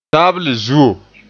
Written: zuo

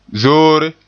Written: zoore

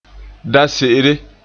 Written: da-se'ere

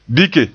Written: bike

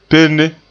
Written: penne

